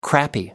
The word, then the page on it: crappy: American English